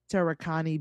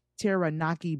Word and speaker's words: Taranaki: As far as how the headless sex doll came to be lying on a quiet Taranaki beach. Wait, said that wrong. Taranaki beach.